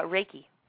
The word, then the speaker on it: Reiki: And, and another thing you do besides um, improv, you have a holistic lifestyle, and is this Reiki? Am I pronouncing that right? – Oh, uh, Reiki.